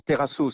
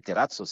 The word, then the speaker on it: terrazzo: Alors, selon la prononciation, on dit terrazzo ou terrazzo. – Exactement. Terrazzo, c'est, disons, on va dire la française, et terrazzo, c'est à l'italienne, bien évidemment.